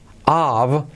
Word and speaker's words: of: Baro, bottle of. Curioso, la F se pronuncia como una V.